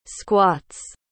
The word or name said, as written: squats